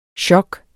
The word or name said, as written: sjok